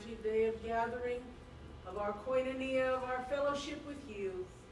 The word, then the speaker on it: koinonia: See, now she knew how to pronounce the word. – Yes. Say that again, please. – Koinonia. – There you go.